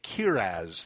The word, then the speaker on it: charaz: And so Paul brings together the evidence that this is true through what is called a kiraz. That's the Hebrew term. It's spelled C-H-A-R-A-Z.